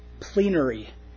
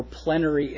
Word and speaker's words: plenary: And plenary inspiration or plenary inspiration. I've heard it pronounced both ways, but it's spelled P-L-E-N-A-R-Y. So verbal inspiration and plenary inspiration. Plenary, that sounds right, doesn't it? Yeah, I've heard it plenary.